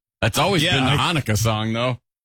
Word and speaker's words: Hanukkah: Like, how do you say it? – The Hanukkah song? – Oh, is that what it is?